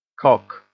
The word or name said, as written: cock